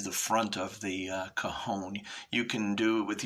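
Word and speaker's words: cajón: And uh, it's pronounced cajon. Um, it's spelled C A J O N with an accent on the O. And uh, the Spanish uh, pronunciation is cajon, and it's box, crate, or a drawer.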